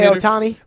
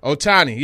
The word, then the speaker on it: Ohtani: And a funny story, the media room that we had, we got kicked out of there because Ohtani has signed, however you pronounce his name, the pitcher. – Shohei Ohtani. – Ohtani, yeah, he has signed.